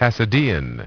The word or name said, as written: Hasidean